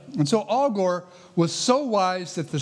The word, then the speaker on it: Agur: A-G-U-R, but it's pronounced Al Gore, like that.